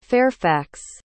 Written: Fairfax

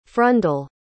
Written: frundel

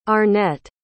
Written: Arnett

